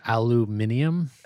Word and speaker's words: aluminium: It's just the Brits say aluminium and you guys say aluminum.